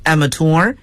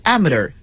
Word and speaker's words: amateur: 那么职业运动员呢，在英语中被称作 professional athlete，但是业余运动员呢，被称作 amateur athlete，或者这个词也可以读作 amateur athlete。